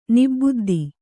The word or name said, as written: ನಿಬುದ್ದಿ